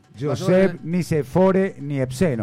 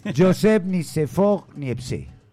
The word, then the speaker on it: Joseph Nicéphore Niépce: Joseph Nicéphore Niépce. No, así no se dice. Joseph Nicéphore Niépce.